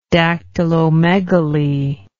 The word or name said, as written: dactylomegaly